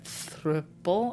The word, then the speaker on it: throuple: But it's not like a throuple. I never know how to pronounce that word. – Throuple. – Throuple. So it's like couple with a Yes.